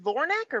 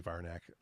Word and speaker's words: Varnak: Is it Vornak or Varnak? Because I swear I have Varnak throughout the rest of my notes. – It's probably Varnak. Okay. It is spelled V-A-R-N-A-K. – Okay. Varnak. That's what I had.